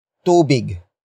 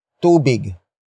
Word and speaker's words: tubig: And that is tubig. Tubig. Tubig.